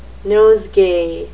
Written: nosegay

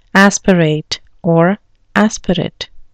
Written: aspirate

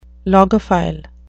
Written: logophile

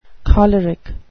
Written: choleric

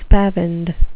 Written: spavined

